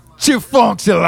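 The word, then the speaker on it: Chefuncte: Chifunkta. – Not at all. – No. What's that? – Chifunkta. – Chifunkta. – No. The T is silent.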